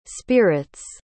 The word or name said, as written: spirits